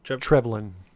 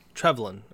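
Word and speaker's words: Trevelyan: How do you pronounce his last name? Trevion? – Trevlin. – Trevlin. Okay, Alec Trevlin, because I kept calling him Alec in my notes, or 006, former 006.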